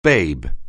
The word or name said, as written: babe